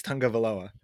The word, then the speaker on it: Tagovailoa: I finally figured out because they kept saying Tagovailoa. That's how to say it. It's Tagovailoa, and Tunga so the other Valoa. The because it's like Hawaiian, so it's like Tunga.